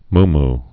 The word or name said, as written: muumuu